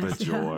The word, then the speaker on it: Bajor: Yes, Bajor.